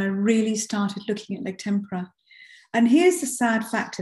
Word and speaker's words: tempera: And I can't finish this conversation, without asking you a little bit about egg tempera. Is that the correct way to pronounce it? – It is, rather than tempera, which is a batter that you dip your fish in.